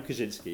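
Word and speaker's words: Kawczynski: Shall we talk about Daniel Kaczynski, if that's the right way to say his surname? – D.K. Shrewsbury. Tory MP. MP for Shrewsbury. – Yeah. – Daniel Kaczynski, yeah.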